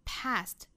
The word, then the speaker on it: past: 我们还是要注意这个 saint 和 wild 这两个单词双元音，从第一个元音过渡到第二个元音，那尤其要定位准第一个元音的口型 wild，后面 past P-A-S-T 美音要读成梅花 A，上下开口度两指左右尽量大开的 past。